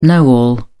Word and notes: know-all: British English

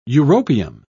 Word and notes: europium: British English

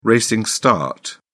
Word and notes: racing start: British English

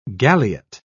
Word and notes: galiot: British English

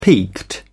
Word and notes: peaked: British English

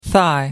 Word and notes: thigh: American English

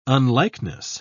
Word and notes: unlikeness: British English

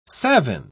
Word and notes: savin: British English

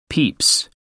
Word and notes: peeps: American English

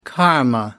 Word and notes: karma: American English

